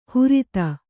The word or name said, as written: ಹುರಿತ